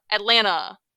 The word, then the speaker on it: Atlanta: And he pronounced it correctly. And then his mom, very mad, pronounced the T in Atlanta. I was like, Who are you? Get your life together. It's Atlanta. Atlanta. Lana. Please.